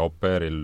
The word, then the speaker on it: Oppert: kirjutatakse eesti keeles opert, aga kahe P-ga, jah. – opeer loeb.